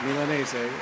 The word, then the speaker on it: Milanese: Right, Milanese.